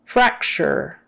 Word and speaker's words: fracture: Fracture. Fracture. Make sure you get an R, not an L. Not flexure. Fracture. Our tongue going back. Fracture. And that CT in the middle is an SH sound.